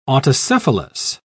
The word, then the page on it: autocephalous: British English